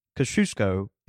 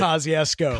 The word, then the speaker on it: Kosciuszko: And so Kosciuszko is pronounced Kosciuszko if you're a real, actual, intelligent person. – I had no clue of that. – But because we're Mississippians, we call it Kosciuszko.